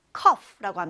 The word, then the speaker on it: cough: 여기 F가 없는데, 단어에 F가 없는데, 왜 F 발음을 하고 있지? 의아하는 분들도 있을 거예요. 맞아요. 여기에서 GH로 단어가 끝나는데, GH는 합쳐서 F 발음이 납니다. 그래서 cough 이렇게 발음해 주시면 돼요.